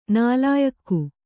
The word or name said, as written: ನಾಲಾಯಖ್